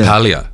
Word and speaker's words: LaPaglia: How do you pronounce his name? – That's it. – Yeah. LaPaglia.